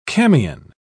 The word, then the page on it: camion: British English